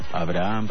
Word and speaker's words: Abraham: está intermedia, se pronuncia como una aspiración. Abraham, pues Abraham. – Sí, pero se pronuncia poco.